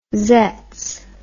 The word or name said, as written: zetz